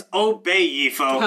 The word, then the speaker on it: Obayifo: It's an Obayifo. – Obayifo. – Obayifo. – Obayifo. – Oh, fuck, I did mispronounce it. It's Obayifo. – Obey. – It's Bay.